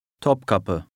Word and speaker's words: Topkapı: Topkapı.